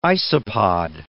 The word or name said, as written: isopod